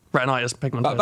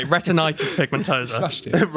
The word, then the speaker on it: retinitis pigmentosa: But you'll know more about retinosa pigmentosa. Retinitis pigmentosa. – That'll do. – See, said it wrong straight away. – I thought you were spitting bars. – I said it wrong anyway. Retinitis pigmentosa.